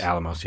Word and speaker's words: Los Alamos: According to him, because there's no other proof, you know, he claims that the materials that he pulled out of people, the Los Alamos, I don't know if I'm saying that right. Alamos, yeah.